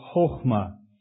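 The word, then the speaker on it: chokhmah (חכמה): And it's the word chokhmah, is how you'd pronounce that.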